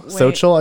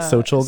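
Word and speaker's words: Xochitl: Oh, Sochil, I believe is how you say. Sochil Gomez.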